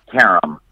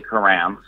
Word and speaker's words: Karam: Karam. – Karam. But a lot of people say Karam, so you know I answer to both.